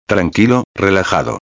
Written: tranquilo relajado

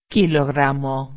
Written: kilogramo

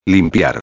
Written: limpiar

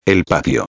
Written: el patio

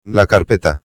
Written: la carpeta

